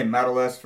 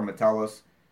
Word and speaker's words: Metellus: He'll say medalist for Josh Metellus. I'm pretty sure Josh has said it before, Metellus.